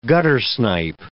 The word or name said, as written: guttersnipe